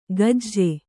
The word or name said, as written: ಗಜ್ಜೆ